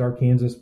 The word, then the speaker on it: Arkansas: We pronounce it Arkansas, but it's spelled like Arkansas.